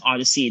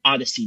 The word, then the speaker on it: Audacy: And just for your information, when I mention Odyssey, it's not spelled like the regular Odyssey. It's spelled differently. A-U-D-A-C-Y. – Yeah, we can find it. – Okay. So yeah, it's an English homonym, FYI.